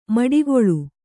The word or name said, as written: ಮಡುಗೊಳ್ಳು